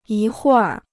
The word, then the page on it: 一会儿: yī huì r